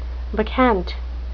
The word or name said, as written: bacchant